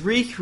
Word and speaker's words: Reek: Corey Kenobi from the United States says, Reek, reek, it rhymes with cheek, and he drew a penis.